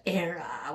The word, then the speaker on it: Erie: It's pronounced Era, which is the Irish word for Ireland.